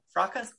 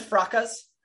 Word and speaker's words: fracas: Fraca? Is the S silent? – I always say the S, but a lot of people say the S is silent. So it depends what mood you're in, really.